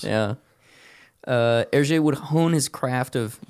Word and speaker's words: Hergé: He created the pseudonym for Hergé by flipping his initials and then using the French pronunciation for them. So instead of G-R, he flipped him to R-G.